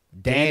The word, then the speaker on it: Danny: I always say Dana because I think of Dana Carvey every time I say his name. – Danny, Danny. Carey. – Danny. I can not say Dana Carvey. Sorry. Danny Carey is 60.